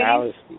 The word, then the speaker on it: Allesee: Actually, Alice.